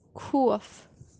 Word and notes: khof: kashmiri

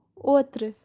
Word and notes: otru: Kashmiri

